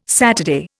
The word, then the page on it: Saturday: Indian